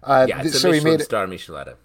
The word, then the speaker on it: Michelada: He made Michelada. Is that how you pronounce it? – Yeah. – Like Michelin star. – Michelada.